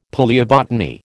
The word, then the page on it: palaeobotany: American English